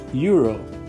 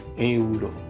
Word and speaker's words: euro: That's in English, of course, and in Spanish it's spelled like E-U-R-O. So we have again two vowels together, E and U, and it's spelled E-U-R-O as well. So we have to pronounce it like E-U, euro, euro. So it's not euro, it's not euro like in German, it's euro, E-U. Next word would be a name, actually a very religious name, and it's M-O-I-S-E-S.